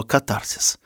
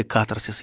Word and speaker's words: катарсис: И тут случается абсолютный, извините за тоже гуманитарное слово, катарсис. Ну или катарсис, если вам больше нравится ударение на первом слоге.